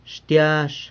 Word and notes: xtiazh: garlic

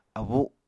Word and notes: abu: apatani

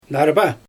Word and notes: narba: Xyzyl

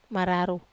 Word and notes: mararou buo: wamesa

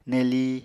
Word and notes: nelii: Apatani

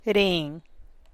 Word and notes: reen: Teotitlán del Valle Zapotec

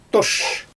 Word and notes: toʃ: Xyzyl

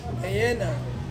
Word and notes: ena: Ansus

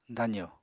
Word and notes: dazo: apatani

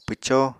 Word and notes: pwcó: apatani

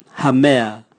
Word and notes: hamea: Raga

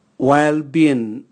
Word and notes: waelbin: Raga

